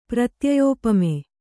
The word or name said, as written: ಪ್ರತ್ಯಯೋಪಮೆ